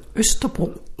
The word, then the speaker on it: Østerport: The English School of Mediumship in Copenhagen in Østerport. Did I say that correctly? – Østerport.